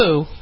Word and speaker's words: Apu: The creator of The Simpsons appears to be suggesting that the blowback over having a white actor voice, is it Apu? Is that how you pronounce Apu.